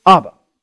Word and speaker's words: Abba: And this is a word that's very familiar to, to some of us, and, and we would pronounce it this way the word Abba.